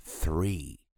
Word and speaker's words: three: Já o número 3, three, three. Com a língua entre os dentes, falando assim, three.